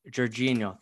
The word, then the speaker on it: Jorginho: Jorginho.